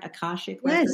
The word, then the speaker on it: Akashic Records: Akashic Records? – Yes, you are.